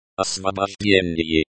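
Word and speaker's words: Osvobozhdeni (Освобождение): Osvobozhdeniye. Osvobozhdeni, stress on the second syllable.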